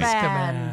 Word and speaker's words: Azkaban: Azkaban.